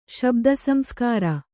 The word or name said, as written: ಶಬ್ದಸಂಸ್ಕಾರ